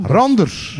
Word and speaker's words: Randers: Je kan met RK Edo naar AZ tegen de Rangers. Zeg ik dat goed? – De Glasgow Rangers? Nee, Randers. Ja. Oh, Randers.